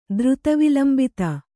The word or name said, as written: ದ್ರುತವಿಲಂಬಿತ